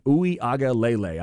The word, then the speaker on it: Uiagalelei: This one is DJ Uiagalelei. That's where I'm going to go with that. Uiagalelei. I believe that's correct.